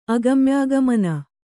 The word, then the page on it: ಅಗಮ್ಯಗಮನ: agamyagamana